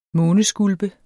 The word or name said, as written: måneskulpe